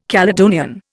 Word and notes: Caledonian: Indian

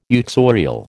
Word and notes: uxorial: American English

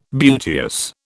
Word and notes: beauteous: British English

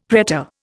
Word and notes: pretor: Indian

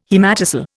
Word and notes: haematocele: Indian

